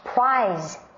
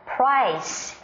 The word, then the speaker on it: prize: 不过我刚才提到了奖品、奖项，用的是这个字，特别小心它的发音哦。 Prize, prize。如果我说 Hey Clayton, price, P-R-I-C-E，那个意思呢就变成是价格了，所以特别小心。